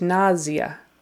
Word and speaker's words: nausea: Actually, it's really pronounced nausea with more of a Z. Nausea. And the primary accent is on the first syllable.